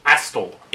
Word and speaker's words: Astle: Astle. – A-S-T-L-E. Astle. – Astle. – Yeah. We're afraid to pronounce it with a silent T because we might be taken off the air.